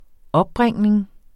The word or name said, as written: opbringning